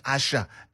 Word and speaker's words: Asa: Asha, written A-S-A, but pronounced Asha, not Asa, as some people would think. Because as I said, the S in Yoruba is pronounced as sh.